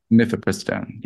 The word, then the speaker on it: Mifepristone: What the name of the abortion drug is, how do you say it? Mifepristone? – Mifepristone, yeah.